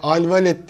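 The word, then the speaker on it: Alwaleed: Alvalet diye okunuyor olsa e,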